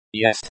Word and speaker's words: Есть: Есть. Pronounced, yest, which can be confusing, since it's spelled and pronounced the same as, to be, in the present tense. You have to pay attention to context. Translation of words. 2. Слишком. S-L-I-S-H-K-O-M, or.